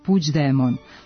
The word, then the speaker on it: Puigdemont: G u kombinaciji glasova IGD od izvornog Puigdemont, kako se na katalonskom piše ovo prezime, izgovara se približno našem dž, ispred kojeg postoji prizvuk glasa i, koji se nedovoljno jasno čuje i lepi se na afrikatu, pa ga nije potrebno prenositi, a d još uvek nije postalo sporno.